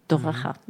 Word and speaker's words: Toraja: país Toraja, que se dice Toraja.